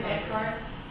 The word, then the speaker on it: Eckhart: Terry Beckhart, 3463 East Quail Avenue, Las Vegas, 89120. My last name is spelled E-C-K-H-A-R-T.